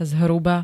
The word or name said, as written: zhruba